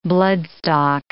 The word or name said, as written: bloodstock